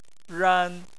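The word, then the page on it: ran: tone 1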